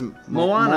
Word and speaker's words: Moana: Moana.